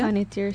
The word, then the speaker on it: Panettiere: Ha sido primero Hayden Panettiere, ¿cómo se pronuncia? – Panettiere, sí.